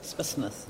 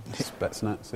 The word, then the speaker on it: Spetsnaz: Is that how you pronounce it? – Spetsnaz. – Spetsnaz, yeah.